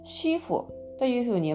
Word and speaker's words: 师傅: なので、えっ、ー、と最初の音「し」に軽く添えるような感じで、えー、発音してみてくださいそしてこの SH は反り舌音ですので口の中で舌を上に反り上げてその左右から息を漏らすようにして「し」と発音しますこれで「シーフ」「シーフ」と言っ